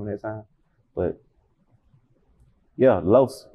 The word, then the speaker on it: Los: Los. It's spelled L-O-S. Like Los Angeles, Los. So that's the, you know what I'm saying?